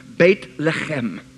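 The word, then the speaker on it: Beit Lechem (בית לחם): Bethlehem in the Hebrew language is pronounced Beit Lechem, which means house of bread.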